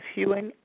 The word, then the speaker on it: Hewing: My name is spelled P as in Pretty Girl, P-E-R-N-E-L-L. Last name is Hewing, H-E-W-I-N-G.